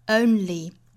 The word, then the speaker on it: only: 后面的 you only, only 这个词英式发法是 own, only.